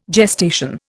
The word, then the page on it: gestation: Indian